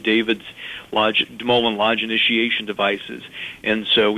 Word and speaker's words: DeMoulin: That is pronounced De Mullen and spelled D-E-M-O-U-L-I-N.